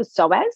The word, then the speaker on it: psoas: It's called the psoas. Have you ever heard of that? It's spelled P-S-O-A-S. So it's a silent P.